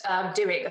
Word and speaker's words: Duhigg: Or, you know, and I think it started with Charles Duhigg, I think is mm-hmm. how you pronounce his surname.